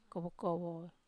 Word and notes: kowo-kowo: wamesa

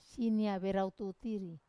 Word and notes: sinia verau tutiri: wamesa

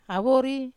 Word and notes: avori: Wamesa

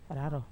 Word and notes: raro ii: wamesa

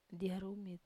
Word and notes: dia rumit: wamesa

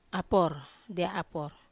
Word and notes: apor: Wamesa

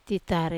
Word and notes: tatare: wamesa

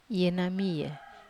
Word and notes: ena mia: wamesa

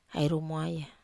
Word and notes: ai rumwaya: Wamesa

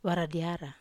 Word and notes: vara diara: Wamesa

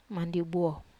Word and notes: mandiu buo: wamesa

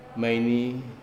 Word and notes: maini: wamesa

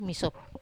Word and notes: masop: wamesa

